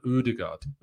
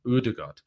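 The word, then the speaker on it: Ødegaard: So it's an O sound, Odegaard, Odegaard.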